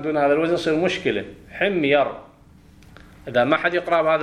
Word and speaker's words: حمير: حمير على وزن درهم، واضح إن شاء الله.